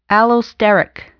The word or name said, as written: allosteric